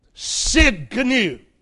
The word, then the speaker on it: Tsidkenu: It's spelled T-S, some use a Z instead of an S, T-S-I-D-K-E-N-U. The T is silent. Tsidkenu. It's an unusual title, but it means righteousness.